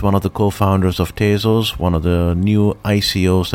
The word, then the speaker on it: Tezos: Yeah, I mean, it's a made-up word. So really, any way you pronounce it is quite fine. But my husband and I pronounce it Tezos. – Tezos.